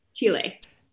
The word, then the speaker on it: Chile: Chile. I never know how to pronounce it. – Chile.